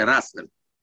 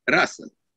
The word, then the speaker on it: Russell: Și aș vrea să vă aduc aici în atenție trei perspective a lui Bertrand Russell, sper că am pronunțat corect, care spune că... – Nu, n-ați pronunțat corect, e Russell. – Russell, ok. – E English, Russell.